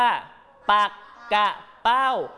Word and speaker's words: ปักเป้า: คำคำนี้อ่านว่าอะไรลูกแน่นอนตัวสีแดงๆอ่านว่าปักกะเป้าปักกะเป้าเป็น